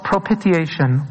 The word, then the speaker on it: propitiation: And I think both the Catholic and the Pauline Epistle today, there's a very important word that illustrates this concept, and everyone always mispronounces it. It's called propitiation. Propitiation.